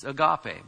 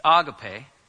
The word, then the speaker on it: agape: In the Bible, you have this word agape, which many people mispronounce as agape, but that's fine. It's agape, because the accent's on the end.